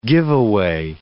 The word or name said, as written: giveaway